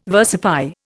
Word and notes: versify: Indian

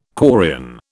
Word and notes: quarrian: British English

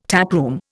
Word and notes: taproom: Indian

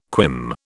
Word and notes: quim: British English

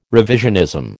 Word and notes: revisionism: American English

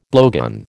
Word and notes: blowgun: American English

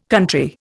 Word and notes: country: Indian

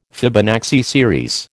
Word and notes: Fibonacci series: American English

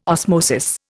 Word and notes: osmosis: Indian

